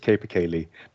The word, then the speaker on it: capercaillie: In Norwegian, it's tiur, but the way we pronounce it in English is capercaillie.